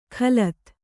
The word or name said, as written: ಖಲತ್